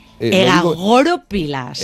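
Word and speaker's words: egagrópilas: Egagoropilas. – Vamos a poner el acento en la O.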